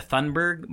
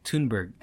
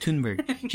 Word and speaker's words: Thunberg: I was gonna say Greta Thunberg, but it's like Thunberg. Yeah, it's like Thunberg.